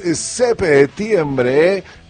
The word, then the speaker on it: septiembre: Y si es 7, es septiembre, con acento en la P.